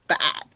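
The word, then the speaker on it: Pfaadt: Or Gavin Stone or Brandon Fat. I pronounced the P. But you just can't hear it.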